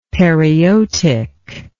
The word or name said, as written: periotic